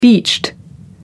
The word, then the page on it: beached: American English